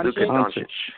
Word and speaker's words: Luka Doncic: Luka Doncic.